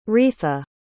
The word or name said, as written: retha